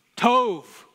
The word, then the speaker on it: tov: Tov.